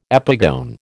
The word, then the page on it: epigone: American English